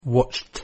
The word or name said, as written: watched